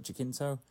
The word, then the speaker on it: Jacinto: How do I say his last name? Jacinto, yeah. And of course Ted Danson.